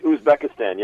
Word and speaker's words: Uzbekistan: So that's the terrorist in question here came under that program in 2010 from Uzbekistan, is that how you pronounce it? – Mm-hmm. Correct. Uzbekistan, yeah. – Uzbekistan.